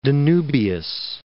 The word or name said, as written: Danubius